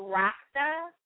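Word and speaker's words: Rakt (रक्त): So it's spelled Rakta, but you don't pronounce it that way. You pronounce it Rakt. You don't pronounce the A.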